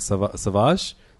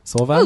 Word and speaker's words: Sauvage: Um, but uh, I did read Unfollow um, by Rob Williams with uh, fill-in artist uh, Marguerite Savage. Sauvage.